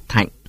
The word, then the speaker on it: Thịnh: Thịnh người Nam Bộ đọc là Thạnh.